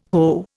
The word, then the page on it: hoe: Indian